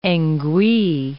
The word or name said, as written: ngwee